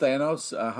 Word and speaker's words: Thanos: Or it can be pronounced Thanos, however you want to say it. You know what I mean? – Yeah. You roll your own. Here's the thing. That's why I think Thanos is so mad, because everyone keeps saying his name in a different way. – He's pronouncing his name, yeah. – I know. That's what you're doing. – Yeah. Or maybe you're doing it. – Thanos like anus.